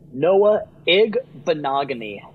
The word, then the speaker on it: Igbinoghene: Noah Igbinoghene. – Igbinoghene. – Igbinoghene.